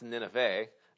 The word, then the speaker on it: Nineveh: It's actually pronounced Nineveh. I wasn't sure about that, but I actually looked it up, and yes, it actually is Nineveh, so we've been saying it wrong all these years, and the Jews are probably laughing at us, but there you go.